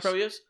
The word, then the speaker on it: Proyas: The director of this film, Alex Poyos. I don't know if I'm pronouncing his name wrong. This is what I was so shocked about. And what Proyas? Proyas.